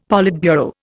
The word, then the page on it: politburo: Indian